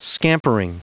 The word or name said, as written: scampering